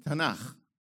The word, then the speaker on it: Tanakh: T and K, when you add vowel, add a little bit of noise in it to make it pronounceable, it's pronounced Tanakh.